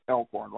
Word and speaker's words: Elkhorn: But the mile and a half turf race, Elkhorn 6, I'm just guessing it's pronounced Elkhorn right after the creek. – Yes, like the creek.